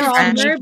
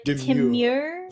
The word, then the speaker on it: Temur: So is it pronounced Teemer or is it Temura? – I always pronounce it Temur, but I'm not too sure. – Okay. I'm just making up accents at this point. Okay. – Like Demure, but Temur? – Temur. – Temur? – Yeah.